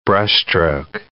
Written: brushstroke

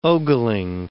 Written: ogling

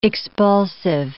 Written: expulsive

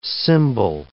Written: cymbal

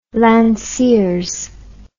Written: lanciers

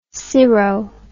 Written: cirro

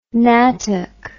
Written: nattock

